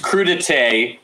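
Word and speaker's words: crudités: It's crudite, but it is spelled with an S at the end. So there we go. Crudites.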